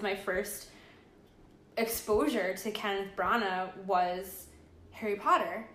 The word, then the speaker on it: Branagh: I heard different people say his name differently. – I've always heard Branagh. – Yeah, Branagh.